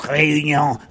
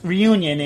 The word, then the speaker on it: Réunion: On the island of, I guess in French it's Réunion, but it's Reunion in the U.S. language here, right?